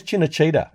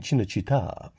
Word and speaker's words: Cinecittà: where's Cinecittà? And they said, you mean Cinecittà? So ever since then, I've had to sort of pronounce it correctly, pronounce it the Italian way, because I remember the look of disdain that the Italian dude gave me when I pronounced it incorrectly.